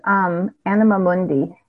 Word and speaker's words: Anima Mundi: Yeah, the brand name is Anima Mundi. – How do you spell that? – A-N-I-M-A M-U-N-D-I. It's two words. – M-U-N-D-I? – Yep.